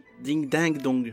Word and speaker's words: Ding Dang Dong: Ding dang dong. Bah ça s'écrit ding dong dong. Mais c'est ding dang dong.